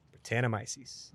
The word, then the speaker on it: Brettanomyces: Brettanomyces. – Oh, am I saying it wrong? – I was like 99% of the way there. I feel like you did well.